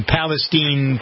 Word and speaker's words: Palestine: John's in Palestine, Texas. Hi, John. – How you doing? – You impressed that I pronounced the name of your town correctly? – Yes.